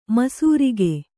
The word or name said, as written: ಮಿಸಿರಿಕೆ